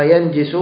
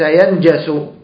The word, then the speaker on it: ينجس: هذا بالنسبة للاسم، وبالنسبة للفعل قلنا نجس ينجس، وربما أنا نطقتها ينجس، إن كنت نطقت هذا فهذا خطأ. نجس من باب فرحة، نكتب هذا حتى ما ننساه، نجس من باب فرحة، فرحة يفرح، نجس ينجس،